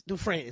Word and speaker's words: Dufresne: Right, it's Dufresne.